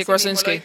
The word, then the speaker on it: Krasinski: Eh, creo que John Krasinski o como se llama ese amigo. Sí, Krasinski. – Lo dije bien y esto salió smoothly, out of my mouth, without knowing or practicing.